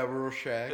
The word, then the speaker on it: Rorschach: It's kind of like a Rorschach test. Am I saying that right? It's like... – Yeah, Rorschach.